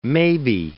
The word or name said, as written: maybe